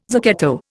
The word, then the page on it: zucchetto: Indian